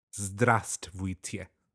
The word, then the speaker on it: Здравствуйте: So and the the stress will be on the first sound pattern. So it'll sound like this. Здравствуйте. Oh god. Здравствуйте. On the second syllable, uh, try to enunciate the I sound more.